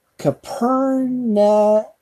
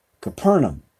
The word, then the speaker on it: Capernaum: How do you say that again? What is that? That's Capernaum. I'm still trying to get all these, as many years as I've been doing this, I am still struggling a bit on the pronunciations on the King James. Capernaum. There you go.